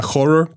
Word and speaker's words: HO99O9: La de Fight Fire with Fire con esta banda Horror, que se escribe H-O-9-9-O-9, que es un grupo como de hip hop metal acá, medio locochón.